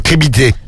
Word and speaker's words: crédibilité: Non, alors tu as oublié une syllabe, tu as oublié le lit. – Crédibilité. – Débilité, c'est comme débilité. Cyril, tu retiens, c'est comme débilité. Crédibilité, débilité, c'est pareil. N'oublie pas le lit, c'est important.